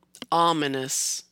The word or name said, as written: Ominous